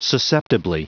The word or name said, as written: susceptibly